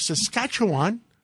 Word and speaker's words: Saskatchewan: We have the uh, premier of Saskatchewan.